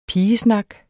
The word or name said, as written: pigesnak